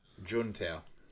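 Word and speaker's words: Juntao: And he says it's a new guy named Guan Tao? Is that how you pronounce it? Guan Tao? – Jun Tao. – Jun Tao, that's it.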